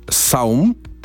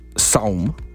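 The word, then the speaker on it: T'som: Wpadłem na jego najnowszy utwór Mama, z jego najnowszego krążka, który się czyta Saum. Saum. Pisze się T-S-O-M i między T a S jest apostrof.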